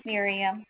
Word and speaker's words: Miriam: My name is Miriam.